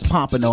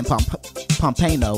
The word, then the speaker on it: Pompano: Just like I didn't know Pompano Beach was Pompano. I was saying Pompano or something like that.